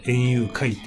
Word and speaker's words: 演幽怪: けどね、他に今に英介さんって人と、山内隆人さんっていう人が出る、園遊会っていう、実話怪談イベントなんですで、まあこれあの、演技の演に幽霊の幽に怪奇の怪って書いて、あの、演遊怪って読むんですけれど、